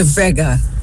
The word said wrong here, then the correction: Lou Bega: De Leiria, e teremos a certeza, é Lou Bega com o Mambo No.